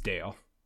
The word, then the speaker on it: Dale: If I remember, they pronounce it wrong in the movie, but it is Dale.